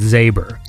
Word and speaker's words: Z6B3R: So I think it's pronounced Zaber, like Saber or, you know, anyway, because it's Z6B3R. Zaber.